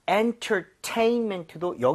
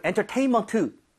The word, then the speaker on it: entertainment: 자, entertainment도 여기 강세를 줘서 여러분 entertainment 이게 아니라 네. – 제가 두 번 들려 드릴게요. 한번 같이 따라해 주세요.